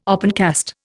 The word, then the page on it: opencast: Indian